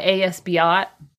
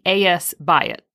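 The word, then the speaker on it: A.S. Byatt: The book is called Angels and Insects by A.S. Biot. I mispronounced the author's name. It should be A.S. Biot.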